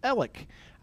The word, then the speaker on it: Alex: My first name is spelled A-L-E-X. The world pronounces that Alex. My family pronounces it Alec. I go by Fitz because people don't get Alec right, and so often I'm called Fritz.